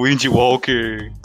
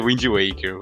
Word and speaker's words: Wind Waker: e o Wind Walker, que na verdade é Wind Waker, mas beleza.